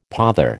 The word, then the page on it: pother: American English